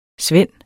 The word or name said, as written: svend